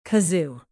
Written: kazoo

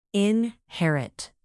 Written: inherit

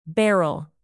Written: beryl

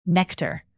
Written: nectar

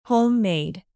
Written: homemade

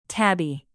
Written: tabby

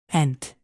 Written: pent

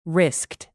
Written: risked

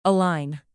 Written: align

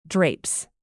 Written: drapes

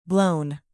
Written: blown

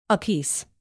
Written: apiece

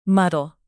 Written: muddle

